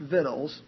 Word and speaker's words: victuals: That's pronounced victuals, spelled as it is in the King James.